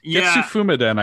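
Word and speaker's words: Getsu Fūma Den: Is that, Getsu Fumiden, I think is how it's pronounced.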